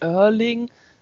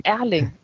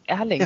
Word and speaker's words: Erling: Ja, das genau, das wird wie O ausgesprochen und der Vorname halt auch nicht Erling, sondern Erling. Erling.